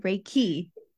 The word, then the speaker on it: Reiki: Reiki.